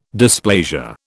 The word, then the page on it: dysplasia: British English